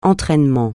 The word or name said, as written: entraînement